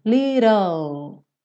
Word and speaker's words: little: Você pega o T e transforma em rararã. Fez o rararã, little, uh, uh, uh, L, língua no sol da boca, ok? E o E, o E não é pronunciado como quase sempre, certo? Então, little, little.